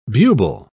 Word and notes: bubal: British English

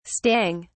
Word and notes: stang: British English

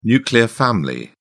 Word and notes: nuclear family: British English

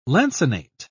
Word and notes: lancinate: British English

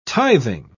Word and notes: tithing: British English

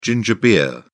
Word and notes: ginger beer: British English

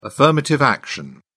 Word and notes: affirmative action: British English

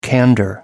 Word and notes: candor: British English

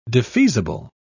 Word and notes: defeasible: British English

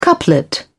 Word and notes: couplet: British English

